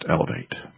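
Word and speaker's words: LAV8: Letter A, letter V, 8. Pronounced Elevate.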